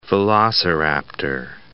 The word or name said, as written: velociraptor